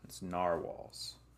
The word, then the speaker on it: narwhal: I've been saying it wrong, too, apparently, my whole life. I always call them narwhals. It's narwhals.